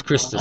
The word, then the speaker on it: Christos: Don't say it like that. – Christos. Christos. – It's Christos.